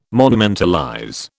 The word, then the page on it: monumentalize: British English